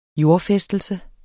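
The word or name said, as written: jordfæstelses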